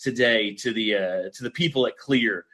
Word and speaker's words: Klyr: want to talk to you about clear rum.